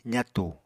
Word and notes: zatu: apatani

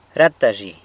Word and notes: rata zhi: sanlucasquiavini

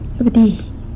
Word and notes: bdi: sanlucasquiavini

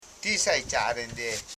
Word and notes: saicharen: sai.cha.ren; Koro

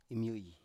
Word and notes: imyoi: Biak

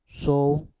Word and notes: sou: mpur

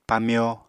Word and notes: pamyo: Apatani

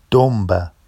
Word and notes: domba: Vafsi

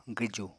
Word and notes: gwju: apatani

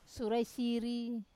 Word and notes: sura e siri: Wamesa

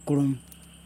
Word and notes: kuluN: Yokoim

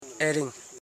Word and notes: ering: e.ring

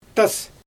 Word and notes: tas: Xyzyl

